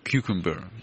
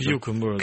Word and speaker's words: cucumber: Правильно я произношу, да, гуречник? Кьюкембер. Вот поправь. – Кьюкембер. – Кьюкембер, да, он, я научился это слово произносить.